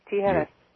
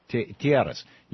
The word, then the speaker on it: Tijeras: Yes, Tijeras. – Tijeras, you're on the air with Bernie Sanders.